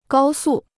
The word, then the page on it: 高速: gāo sù